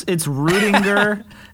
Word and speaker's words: Rüdinger: Pretty much. It's Rüdinger. – It's close. – Kind of like with a hard U or whatever, but okay.